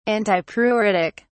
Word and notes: antipruritic: British English

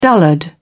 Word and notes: dullard: British English

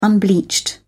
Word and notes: unbleached: British English

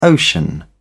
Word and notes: ocean: British English

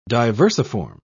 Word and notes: diversiform: British English

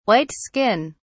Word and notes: white skin: British English

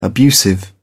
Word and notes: abusive: British English

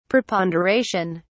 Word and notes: preponderation: British English

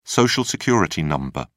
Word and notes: social security number: British English

